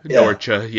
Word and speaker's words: Norcia: Norcia, yes.